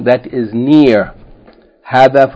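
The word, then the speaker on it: hadha (هذا): And hadhihi is pronounced as though the first syllable is long, hadhihi, in the same way as hadha is pronounced hadha, as though there's an aleph, even though it is not written.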